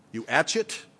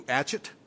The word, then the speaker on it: Uatchit: There was a god represented by a fly, Uatchet, if I'm pronouncing it properly, Uatchet. I'll spell it for you, and you can pronounce it whatever way you want to. If you know an Egyptian who speaks the lingo, then fine. This god's name is spelled U-A-T-C-H-I-T. To me, it looks like Uatchet.